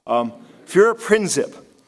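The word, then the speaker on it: Führerprinzip: Für ein Prinzip. And Helga, if you could help me, I know I butchered that, I'm sorry. Or Gerda, please, forgive me. Führer Prinzip, it means the Führer's word is above law.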